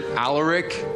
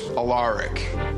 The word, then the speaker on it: Alaric: You'll probably want to pronounce it Alaric, but it's Alaric, okay? So you can call me Rick.